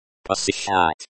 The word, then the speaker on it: Посещать: Посещать. Посещать, start with the soft P sound, and the O sound similar to the English O, but slightly shorter. Then, the S sound is like in English.